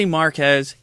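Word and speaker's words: Marques: And then Marcus, anybody who... That's how you say his name? Marquez? Marquez. – I say Marquez. He doesn't care if he's Marcus or Marquez.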